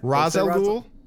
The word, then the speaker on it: Ra's al Ghul: Say it right. – Ra's al Ghul. – It's not right. Saying, you like right? Ra's? You like Ra's? – It is Ra's. That is Ra's al Ghul is correct.